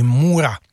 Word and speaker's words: Moorach: Zahrajeme si pražskou kapelu Můra, psáno Moorach. Vyslovuje se to prostě klasicky Můra.